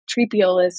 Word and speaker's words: Triepeolus: And then the other one is Tripeolus, which is spelled T-R-I-E-P-E-O-L-U-S.